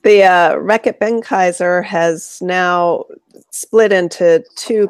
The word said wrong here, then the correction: Reckitt Benckiser: And honey, you said it wrong. – I can't say it like you do. – Reckitt Benckiser. – There you go.